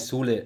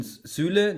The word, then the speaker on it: Süle: Sulu heißt der, nicht Sule. Also, Zulu, jetzt ich bin, der heißt Sulu, der heißt. – Zulu. – Jetzt bin ich durcheinander gekommen zwischen Süle, den habe ich falsch mit Sule ausgesprochen.